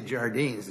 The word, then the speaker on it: Jardines: I say Jardines. Is that the one you're talking about? – Yes, Your Honor. – I've never heard it pronounced with an H, but so I was wondering if I was thinking of the right case.